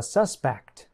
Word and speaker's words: suspect: And notice the pronunciation changes when the verb becomes a noun. Larry is a suspect.